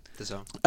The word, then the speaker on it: DAZN: But like the alternative was like DAZN, how you pronounce that? – Yes. – DAZN. – DAZN. – DAZN. Is it DAZN? DAZN, yeah. DAZN.